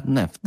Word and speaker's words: NFT: La verga, The Verge, ha deciso che si pronuncia neft. Non so perché, non so come l'abbiano deciso, ma è quello così. E quindi d'ora in poi diremo i neft.